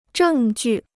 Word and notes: 证据: zhèng jù

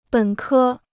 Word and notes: 本科: běn kē

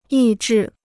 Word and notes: 抑制: yì zhì

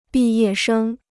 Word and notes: 毕业生: bì yè shēng

